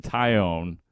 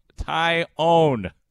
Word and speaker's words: Taillon: By the way, Taillon, not Taillon, Taillon. Man, I kind of liked it when Taillon was injured because then nobody mispronounced his name. It was great. Taillon, I know, it looks like Taillon.